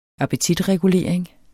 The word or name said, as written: appetitregulering